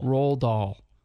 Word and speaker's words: Roald Dahl: Roald Dahl. Like you roll a doll. Is that a pen name? – R-O-A-L-D.